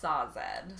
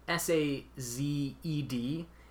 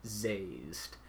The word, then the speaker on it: Sazed: But it is spelled S A Z E D. Yeah. And in my brain, I will probably always say zazed. – Yeah, I just assumed like it's spelled exactly like dazed, so it should be sazed, but.